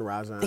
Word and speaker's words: Rajon: Rajon.